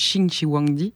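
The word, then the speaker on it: Qin Shi Huangdi: En fait, ça se prononce Qing Shiwangdi, mais ça s'écrit avec un Q, donc je le prononcerai Qin, pour l'accompagner donc dans son repos éternel, ce qu'il pensait être son immortalité.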